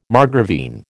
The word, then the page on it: margravine: American English